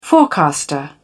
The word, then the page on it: forecaster: British English